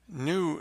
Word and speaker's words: GNU: New is a term you're going to hear in Linux circles a lot. New, which is spelled GNU, technically is an OS that is free software, that is, it respects users' freedom.